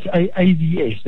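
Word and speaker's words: AVeS: Okay, and you are the CEO of Avis. Is it Avis? Because I know it's capital A, capital V, small letter E, capital S. – Yeah, it's AVS. The E is silent.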